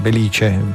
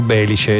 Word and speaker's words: Belice: Attenzione, l'accento è come diciamo noi, Belice. Poi a un certo punto ci fu che proprio durante una radiocronaca, una telecronaca, dissero Belice, e da quel momento è diventato Belice, no, è Belice.